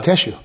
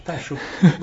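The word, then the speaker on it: Tesshu: I may be completely mispronouncing it. It's T-E-S-S-H-U. – And, sounds like Teshu. – Teshu.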